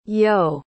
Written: yeo

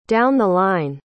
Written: down-the-line

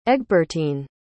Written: egbertyne

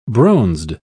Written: bronzed

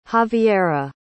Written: javiera

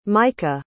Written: meika

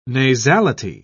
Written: nasality

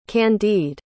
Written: candide

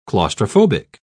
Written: claustrophobic

